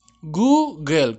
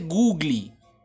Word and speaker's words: Google: Google, Google, como que fuera a botar un pollo, Google, así. No se dice Googly. Google, no se dice así, se dice Google.